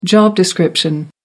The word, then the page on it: job description: American English